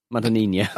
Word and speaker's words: Madonnina: Imagine Madonna, but with Ina at the end. Madonnina.